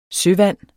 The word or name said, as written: søvand